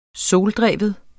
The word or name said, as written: soldreven